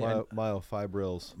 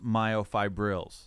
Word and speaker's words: myofibrils: Isn't like, you know that when you get the mass, hypothetically, right, you can get more of the myofibrillars. Did I say that right? – Myofibrils. – Myofibrils. – Yeah.